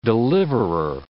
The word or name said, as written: deliverer